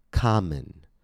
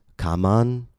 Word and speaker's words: common: Common. Notice how the second letter O changes quality. It goes from A to A. Common. The word is not pronounced kaman or kamon, but common. Ka-mun, common.